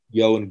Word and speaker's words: Ion: It's spelled, so my name is spelled I-O-N, and then my second name, G-R-I-L-L-O, Yoan Grillo.